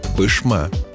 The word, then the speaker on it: Пышма: Есть в России названия городов, которые неправильно пишут и произносят даже сотрудники СМИ. Одно из них – Верхняя Пышма в Свердловской области. Да, именно так – Пышма.